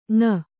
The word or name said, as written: ಙ